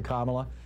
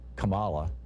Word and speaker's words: Kamala: I realize there may be some challenges to Kamala. Interestingly, the song pronounced it Kamala, but I think it's actually pronounced Kamala, like calm Allah.